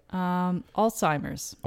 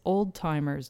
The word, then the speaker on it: Alzheimer's: Alzheimer's. So it's a horrible disease, but it's a disease that is also horribly mispronounced at times as old timer's disease.